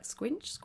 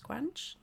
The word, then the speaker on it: Squanch: Because I've had a few people like, Squinch, Squanch. So it's Squanch.